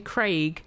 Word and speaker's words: Craig: People were asking us a few months ago, they were making us slag off American pronunciations, and I forgot that the way that Americans pronounce the name Craig to rhyme with egg troubles me.